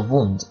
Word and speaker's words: wand: WAND 呢就是其实是 Harry Potter 他们都会有的那个就是魔杖的意思，然后大家注意一下它的发音， A 在这个地方呢发的是 O 这样一个短音，而不是 A 也不是 R，读作 WAND。